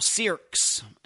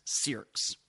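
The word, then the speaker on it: Cyriacks: Next update, Rachel Sierks, and that's C-Y-R-I-A-C-K-S. Forget about the spelling of that, but that's how it's pronounced, Sierks.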